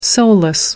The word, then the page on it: soulless: American English